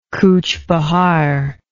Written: coochbehar

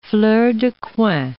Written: fleur de coin

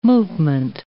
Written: movement

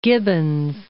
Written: Gibbons

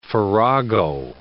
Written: farrago

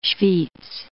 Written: Schwyz

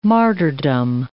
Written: martyrdom

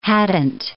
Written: hadn't

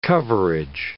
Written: coverage

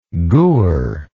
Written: gur

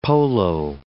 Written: polo

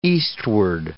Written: eastward

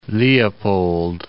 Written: Lake Leopold II

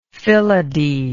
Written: phyllody

